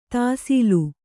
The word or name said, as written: ದಾಸಳ